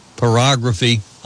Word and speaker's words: pyrography: And coming up on Sunday, February 27th, wood burning or polygraphy classes. I asked Kathy how to pronounce that. I thought it was pyrography. I'm wrong. Pyrography classes. This is the free-handed art of decorating wood with burn marks, or as the Greeks say, writing with fire.